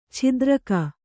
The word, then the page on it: ಛಿದ್ರಕ: chidraka